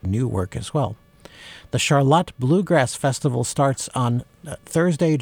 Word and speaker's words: Charlotte: It's spelled the way Charlotte is, but it is pronounce Charlotte. And that's one of those words, you know, like when you have Milan and Milan. If you're from central Michigan, if people say Charlotte, they know you're local.